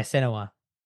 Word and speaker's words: Senua: Is it Senua? – Yeah, Senua.